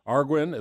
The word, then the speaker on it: Arguin: Arguin, is that close? – You got it right.